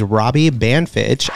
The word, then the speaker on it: Banfitch: His name is Robbie Banfitch.